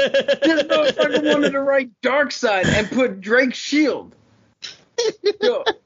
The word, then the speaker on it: Darkseid: Yo, but it's Darkseid. – Darkseid. There you go. I just didn't say it right. – It S-E-I-D.